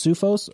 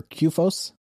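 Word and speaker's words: CUFOS: It is worth noting, right, so I think Rob kind of mentioned, did you say, is it pronounced Sufos or Qfos? – Qfos. – So I say Qfos, Qfos. Yeah.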